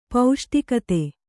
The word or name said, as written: ಪೌಷ್ಟಿಕತೆ